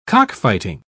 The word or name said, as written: cockfighting